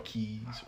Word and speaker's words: Kez: He partnered with entrepreneur, I don't want to mispronounce his name, but it's, I guess, Kaz, K-E-Z, Keys Reed.